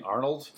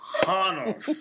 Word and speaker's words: Honnold: Are you saying Arnold? – No. Honnold. Honnold. H-O-N-N-O-L-D.